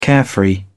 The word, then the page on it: carefree: British English